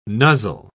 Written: nuzzle

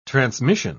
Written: transmission

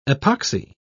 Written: epoxy

